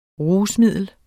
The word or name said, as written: RUSMIDLET